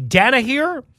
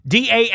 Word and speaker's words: Danaher: Rales is the co-founder of the Danaher, I believe is how you say it, Danaher Corporation, which is DC's largest company.